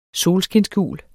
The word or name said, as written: solskinsgul